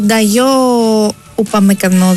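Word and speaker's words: Dayot Upamecano: Ο Νταγιό Ουπαμεκανό, διαβάζεται. – Ναι.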